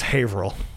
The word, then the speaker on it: Haverhill: It's pronounced Haverhill.